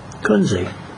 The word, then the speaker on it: Kunze: Last name is spelled K-U-N-Z-E. Kunze.